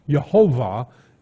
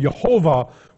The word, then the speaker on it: Jehovah: And so they started actually pronouncing the word the way it was pointed, Jehovah, instead of reading the rabbis wanted them to read.